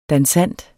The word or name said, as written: dansant